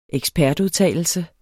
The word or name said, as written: ekspertudtalelse